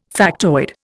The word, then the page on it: factoid: Indian